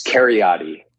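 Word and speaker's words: Kheriaty: It's Cariotti.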